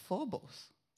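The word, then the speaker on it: phobos (φόβος): Looked up that word reverence in the Greek, and it's phobos.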